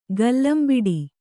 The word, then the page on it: ಕಲಂಬಡೆ: Kannada